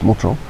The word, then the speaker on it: Montjean: Dans un village qui s'appelle Montjean, parce qu'ils se prononcent comme ça, Montjean. Voilà. Moi j'aurais dit Montjean, mais ils disent Montjean.